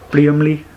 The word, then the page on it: Blüemli: solothurnisch; wasseramt